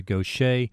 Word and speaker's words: Gauthier: Mary Gaucher, and please pronounce it correctly, G-A-U-T-H-I-E-R. Yeah, just like our programmer, Shay. He spells that different, but they both sound the same.